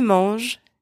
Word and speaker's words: mangent: So after il and elle, in the plural, the letters ENT are silent. Let's see some examples. Ils mangent des fruits.